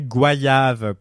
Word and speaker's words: goyave: Et d'ailleurs, la conclusion, c'est prononcer goyave comme voyage. Tout à fait. Euh, goyave, goyave.